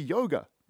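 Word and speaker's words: Jóga: Everybody loves fucking Hyperballad, Yoga, which I guess would be yoga. If it's Björk, it should be yoga. It's a soft J, right?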